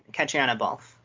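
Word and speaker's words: Caitriona Balfe: Catriona Balfe.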